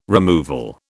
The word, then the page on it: removal: British English